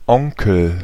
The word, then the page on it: setä: /ˈse.tæ/